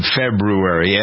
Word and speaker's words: February: Make sure that your youngsters are pronouncing, the teachers are pronouncing, the world is pronouncing February. It's like library. There's an R, actually several R's in February. February.